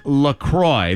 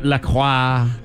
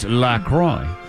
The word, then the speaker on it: LaCroix: Anyway, it is, and in fact, it is LaCroix. That's how you say it. – Okay. – It's not LaCroix or anything. – Blah, blah, blah, blah. – Blah, blah, blah. No. It comes from this. It's a cross between the city in Wisconsin where it's made, La Crosse. – Oh, okay. – And the St. Croix River. So it's LaCroix.